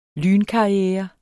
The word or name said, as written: lynkarriere